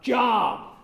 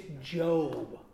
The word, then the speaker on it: Job: The first surefire giveaway that someone has never read the book in question is if they pronounce the book title Job. Okay, right there, if you say that, they'll know you've never read it. Okay, it's Job.